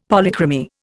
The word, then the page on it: Polychromy: Indian